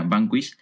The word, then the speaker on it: Vanquish: Em, eh, Gonzalo es eh, especialista de marketing deportivo en, en, una agencia de marketing deportivo que, si no me equivoco, es se, se pronuncia Banquist y que bueno,